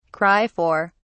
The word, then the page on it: cry for: British English